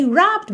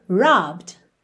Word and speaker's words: robbed: O final dele é ed, mas o e não é pronunciado, certo? Então você não diz robbed e sim robbed. Robbed. Tá bem? Do B você já vai pro D. Agora, se eu digo para você, eles roubaram minha carteira.